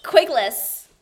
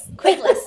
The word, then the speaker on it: Quigless: Quigless. Quigless. Yeah. That Q just freaks everybody out.